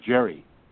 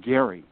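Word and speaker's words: Gerry: one of the anecdotes that we have laughed about on our trips is about a guy named Elbridge Jerry. That's actually pronounced Gary, I found out.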